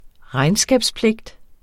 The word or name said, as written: regnskabspligt